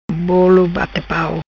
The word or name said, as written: Bolo Bate Pau